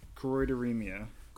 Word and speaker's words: choroideremia: No matter how you slice it, EJ is battling CHM, or as it's pronounced, choroideremia.